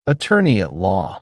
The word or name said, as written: attorney